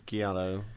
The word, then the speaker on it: giallo: Is it Giallo or Giallo? – One of the two. – What? – Gallio? – No, it is Giallo.